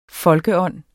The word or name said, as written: folkeånd